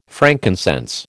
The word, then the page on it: frankincense: American English